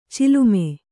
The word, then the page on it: ಚಲುಮೆ: calume